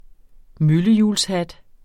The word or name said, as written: møllehjulshat